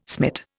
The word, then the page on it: smit: Indian